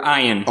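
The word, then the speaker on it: ayin (עין): The word ein. – The word ayin. – Or ayin. – Yeah, ayin is ayin.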